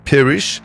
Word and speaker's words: Paerish: Ça s'écrit P-A-E-R-I-S-H. Alors ça doit se dire Perish.